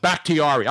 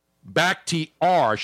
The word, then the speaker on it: Bakhtiari: Bakhtiari. – I even phonetically spelled it. Back, B-A-K-K-T-E-E-R-E-E. Bakhtiari. Should have put the hard R yes.